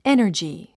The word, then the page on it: energy: énə r dʒi; エナジィ